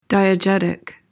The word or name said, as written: diegetic